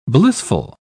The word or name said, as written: blissful